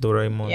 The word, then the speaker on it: Doraemon: it's pronounced Doraemon.